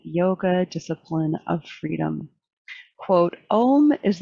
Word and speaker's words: Om: Human beings may reproduce it by extending and strengthening the open compound vowel sound, ow, with the nasal sound, mmm. The ow is generated deep in the body and is brought out through the nasal mm, which then resonates in the head.